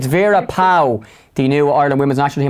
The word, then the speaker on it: Pauw: Vera Pauw, is it Pauw? Am I pronouncing that correctly, Vera? – It's actually Pauw.